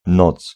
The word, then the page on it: noc: [nɔt͡s]